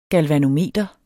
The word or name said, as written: galvanometer